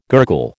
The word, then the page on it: gurgle: American English